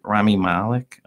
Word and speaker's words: Rami Malek: Say it, Rami Malek.